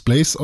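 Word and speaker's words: Blaz: Äh, wird es Blaze ausgesprochen? Mhm. Weil da kein E ist.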